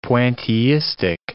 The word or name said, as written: pointillistic